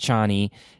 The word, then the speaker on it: Chani: Okay, so she plays Paul's love interest, Chani. It sounded like Johnny to me in the movie, but I don't know. They were a little loose with that. Chani makes sense. It's spelled Chani.